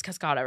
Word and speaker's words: Cascada: It's Cascada, right? – Yeah.